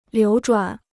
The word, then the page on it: 流转: liú zhuǎn